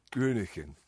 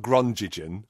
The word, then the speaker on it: Groningen: That's how you pronounce it, because I made people say it several times to me. Groningen. Not Groningen, which is what I'd been saying before.